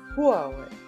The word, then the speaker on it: Huawei: Nee, nicht kein Huawei, also ein Huawei. – Huawei. – Genau, nee. Und dann muss man Y aussprechen, weil im Deutschen Huawei meistens E-I-I ausgesprochen wird. – Ja, stimmt, ja. – Wie das Hühnerei.